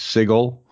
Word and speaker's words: Sigil: it's pronounced Sigil. And the other half is it? – The other half doesn't care. So they at the beginning of it, in the first box set, they made a big thing a couple of times that it had a hard G, that that's how they pronounce it.